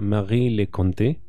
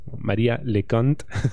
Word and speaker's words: Marie Leconte: Marie Leconte y lo pronuncio así como si fuera francés porque si lo dijese más españolizado, María Leconte. Cant suena a C-U-N-T, ¿no?